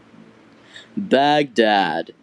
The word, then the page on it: Baghdad: English